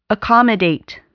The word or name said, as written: accommodate